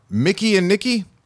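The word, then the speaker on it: Mikey and Nicky: Mickey and Nikki, even though it looks like it's spelled Mikey and Nikki.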